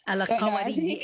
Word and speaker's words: Al-Qarawiyyin: It's a very difficult word to say if you Al-Qarawiyyin. Yeah, there's that emphasis on the Y.